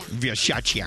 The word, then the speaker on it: Versace: Versace. – Mm, we love it, but it's so disturbing. – That's how you say it correctly.